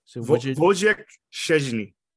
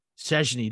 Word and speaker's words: Wojciech Szczęsny: So Wojciech Szczęsny. – Szczęsny, that's what it is, Szczęsny.